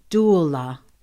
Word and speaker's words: doula: She is a consciousness doula. Did I say that right? – Doula. That's correct.